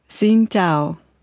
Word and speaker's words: Xin chào: Так, тогда, подождите, давайте научимся правильно говорить приветствие. – Xin chào. – Xin chào. – Xin chào. – Xin chào. – Xin chào. – Правильно.